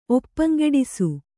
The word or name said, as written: ಒಪ್ಪಂಗೆಡಿಸು